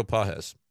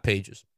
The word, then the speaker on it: Páez: And by the way, another one that people may know, may not care, but I did ask Pedro Páez how to say his name, and it is Pedro Páez. So just say no, not Pages.